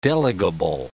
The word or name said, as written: delegable